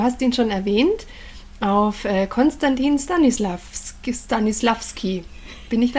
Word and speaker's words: Konstantin Stanislavski: Konstantin Stanislavski.